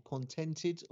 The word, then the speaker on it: Contented: How did you say it though? Content Ed or Contented or Content Ed? – Good question. I like Content Ed.